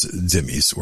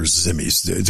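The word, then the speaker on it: dhimmi: So you, initially it was the Jews, then it was the Christians, and then it was all non-Muslims, known as Dhimmis or Zimmis, depending on your pronunciation of that word.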